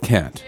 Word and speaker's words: Kant: I don't like the whole pronunciation of Immanuel Kant. Kant, Kant, Kant. To me it's Immanuel Kant.